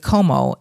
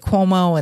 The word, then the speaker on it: Como: And then we drove up to Lake Como and we were going to spend some time in Lake Cuomo. And then we were going to Switzerland. – You say it like Mario Cuomo.